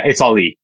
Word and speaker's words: Aly Orady: Um, your pronounced name Aly Orady, Aly, Aly Orady. – Yeah, it's Aly. Yeah, it's Aly Orady. It's um, it's like Muhammad Ali, but without the Muhammad.